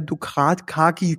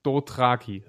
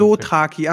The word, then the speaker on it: Dothraki: Und dann kommt der Mormont verängstigt zurückgeritten und ein paar der Dukrat, Kaki, keine Ahnung, wie die heißen. – Nein, wir zurück. haben es beide falsch gesagt. Das ist Dothraki. So, jetzt. – Dothraki, ah, okay.